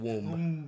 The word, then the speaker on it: womb: I love how he actually pronounced the B in womb. – Roomba. – Like Roomba. – Like a Roomba.